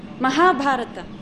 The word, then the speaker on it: Mahabharata: Maha Bharata. This is a short syllable. Ha is long because it has long A in it. Ba is also long, long A. Many devotees say Mahabharat. Mahabharat. Mahabharat. Mahabharata.